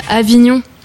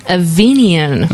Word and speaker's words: Avignon: Mais j'ai remarqué, vous prononcez tous mal, en fait. Vous dites Avignon, alors que ça se dit Avignon.